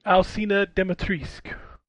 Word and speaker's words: Alcina Dimitrescu: Alcina Dimitrescu. And by the way, they say her name in the game. So that is the correct pronunciation.